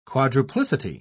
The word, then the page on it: quadruplicity: British English